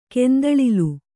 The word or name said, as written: ಕಂದೊಳಲಿ